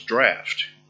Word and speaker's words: draught: Which that looks like draught. Yeah, I always thought it did too, but it's spelled the same way as laugh without the T, right? And so it's draft.